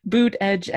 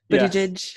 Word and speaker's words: Buttigieg: Boot, edge, Buttigieg. Yes.